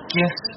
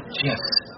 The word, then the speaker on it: GIF: Those little animated pictures on the internet, are they called GIFs or GIFs? – Well, the G stands for graphics. That's a hard G, so I said GIF. – But the guy who invented it says it's GIF.